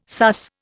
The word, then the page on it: sus: Indian